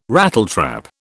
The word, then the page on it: Rattletrap: British English